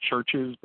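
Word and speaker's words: CHVRCHES: I'm going to say it's pronounced Churches, but it's I really like them.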